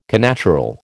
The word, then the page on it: connatural: American English